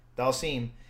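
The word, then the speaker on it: Dhalsim: Having Dazlem, is it Dazlem or Dazlem? I forget how to pronounce his name. – Dalsim.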